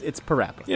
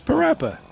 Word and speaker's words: Parappa: Agreed with me, it's Parappa. – Yeah, it's Parappa. They say Parappa in the game.